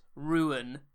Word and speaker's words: Ryun: Introducing Ruin, R-Y-U-N, should be pronounced Ruin.